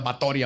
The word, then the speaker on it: Báthory: La condesa Erzbet Batori de Exed, porque le se, se pronuncia Batori aparentemente, nació en Nibrator, en Hungría, en lo que ahora se conoce como Eslovaquia y Rumania, el 7 de agosto de 1560.